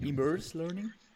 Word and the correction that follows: Immersive Learning: Immersive Learning. – Immersive ja, genau.